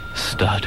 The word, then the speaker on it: Studd: Stud. – How do you spell that? S-T-U-D-D? – Second D is optional, obviously.